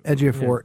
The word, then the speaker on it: Ejiofor: The guy's got the most unpronounceable name, Chiwetel Ejiofor. – Chiwetel Ejiofor. – Yeah, Ejiofor.